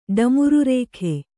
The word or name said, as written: ಡಮುರುರೇಖೆ